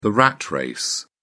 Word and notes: rat race: British English

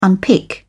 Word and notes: unpick: British English

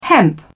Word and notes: hemp: British English

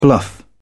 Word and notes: bluff: British English

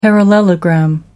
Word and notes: parallelogram: American English

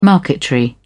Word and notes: marquetry: British English

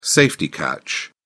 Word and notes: safety catch: British English

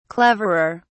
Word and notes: cleverer: British English

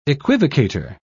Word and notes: equivocator: British English

